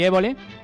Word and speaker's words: Évole: Juan y Medio estuvo con Jordi Évole.